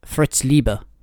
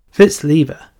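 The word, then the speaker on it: Fritz Leiber: How do you pronounce this author's name? – Fritz Lieber. – Fritz Lieber. – Yeah, you can thank my love of German musical theatre for that.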